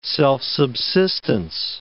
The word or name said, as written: self-subsistence